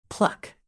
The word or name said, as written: pluck